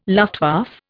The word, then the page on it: Luftwaffe: Indian